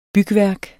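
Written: BYGVÆRKER

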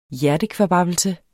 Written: hjertekvababbelse

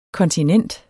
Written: kontinent

